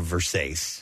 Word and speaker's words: Versace: Versace. – Right. Yeah. – I guess Versace. – Some people say no, Versace. – Versace. – V-E-R-S-A-C-E.